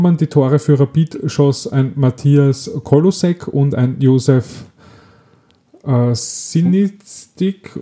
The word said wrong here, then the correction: Smistik: oder so. – Wie? Smistik. – Smistik, ja.